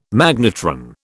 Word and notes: magnetron: British English

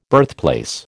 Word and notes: birthplace: American English

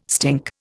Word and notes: stink: Indian

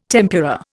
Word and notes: tempura: Indian

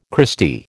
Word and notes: Christy: American English

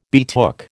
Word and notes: betook: American English

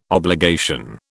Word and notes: obligation: British English

